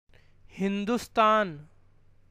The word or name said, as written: Hindustan